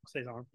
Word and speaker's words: Saison: How do you say that? – Saison. Yeah.